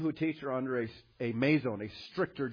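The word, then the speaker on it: meizon (μείζων): First, this adjective spelled, it's meizon, which would be spelled in English M-E-I-Z long O-N, meizon, which is a masculine adjective, which is a comparative adjective to, we get it from the word megas, like mega, big, right?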